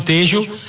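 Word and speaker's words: Tello: se escreve Telo, mas é Facundo Tejo, assistentes Juan Pablo Belatti e Gabriel Chad, arbitragem argentina para o jogo de França e Marrocos.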